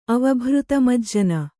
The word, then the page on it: ಅವಭೃತಮಜ್ಜನ: Kannada